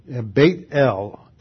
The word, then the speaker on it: Beth-El: That's why I pronounce it that way. Beth is the Hebrew word for house, Beit. It's the second letter in the alphabet. Beit-El. El is the generic word for God.